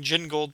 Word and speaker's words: Gingold: Martin Gray from Too Dangerous for a Girl said, "It was funny hearing Gingold pronounced with a soft G. The gin bit makes it sound so much naughtier."